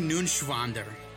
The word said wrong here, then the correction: Neuenschwander: You didn't pronounce it right. Anthony? – Anthony Nunschwander. – It's N-E-U-E-N. It's Neuenschwander. Anthony Neuenschwander.